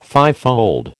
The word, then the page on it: fivefold: American English